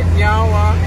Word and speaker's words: nyawa: So in our language, we say nyawa, which means thank you. – Nyawa. – I said it right? – Yeah, you did.